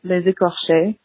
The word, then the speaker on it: Les Écorchés: The episode title is pronounced Les Écorchés, and écorcher as a verb means to flay.